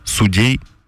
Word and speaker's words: судей: Судья, судьи, судью, но множественное. Судьи, судей, судьям. – Угу. – Вот так вот. Это русско-советское ударение. – Сейчас я пойду проверю. Поэтому судей, Марина.